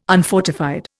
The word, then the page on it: unfortified: Indian